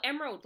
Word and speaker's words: Emerald: Am I saying that right? Well, Emerald, like the stone. – Like the gemstone? – Yeah, the gemstone, Emerald.